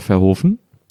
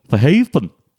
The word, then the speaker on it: Verhoeven: Ich habe mir mal sagen lassen, dass man ihn Verhoeven ausspricht. – In Amerika. – Ach so, ja. – Ist er eigentlich so spricht man ihn wirklich aus. – Eigentlich ist er Holländer. – Ja, ja, so spricht man ihn holländisch aus.